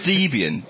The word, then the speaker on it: Debian: Everyone just pronounces Debian in a different way. – Debian. – Debian. – It's Debian. Debian. Debian. – But it's Debian. – I know. – It's from Deb and Ian.